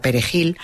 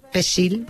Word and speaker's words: perejil: Los soldados de Trujillo detectaban a los que había que matar haciéndoles pronunciar la palabra perejil, porque aunque supieran castellano, esa palabra no sabían pronunciarla. Y si decían, en vez de perejil, decían pesil, pues, que es como lo llamaban, sí, sí, pesil, lo agarraban y lo mataban.